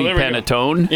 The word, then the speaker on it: panettone: Mini panettone.